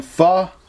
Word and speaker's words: pho: If you've ever had pho soup, it's spelled P-H-O. But it's like what you don't want your kids saying, pho, but you leave off the other letters. That's how you pronounce it, pho soup.